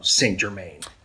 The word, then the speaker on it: Saint Germain: And there may be a fancier way of pronouncing it in France, but the New Orleans version, they just call it Saint Germain.